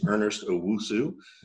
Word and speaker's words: Ernest Owusu: Been there about 18 months, give or take, has a good sales career, and his name is Ernest Owusu.